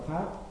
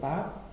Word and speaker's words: táo: Táo, mà cũng có chỗ đọc là tháo, nhưng mà trong bài này đọc là táo.